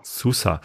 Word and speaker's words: Sousa: Oder Sousa, wie spricht man den aus? – Cauli Oliveira Sousa. – Sousa.